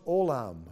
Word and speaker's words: olam: And the word everlasting there is pronounced olam. It's a Hebrew word, olam.